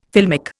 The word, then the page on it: filmic: Indian